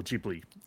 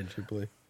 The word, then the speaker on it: Ghibli: But it's also the first game, at least, is in collaboration with Studio Ghibli, or Ghibli, I should say. I guess that's how you say it, but anyway. – Yeah, Ghibli.